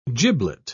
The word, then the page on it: giblet: British English